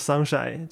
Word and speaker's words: 三辉: 就是写作三辉，念作 sunshine，就是大概这样。